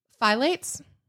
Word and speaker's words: phthalates: What is that word? – Phylates. – Why is it spelled like that? It should be F-I-L-A-T-E.